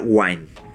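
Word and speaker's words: Gwynne: En cuanto a SpaceX, pero creo que se pronuncia Wine. Lo voy a deletrear, es G-W-Y-N-E. Así es como se escribe su nombre.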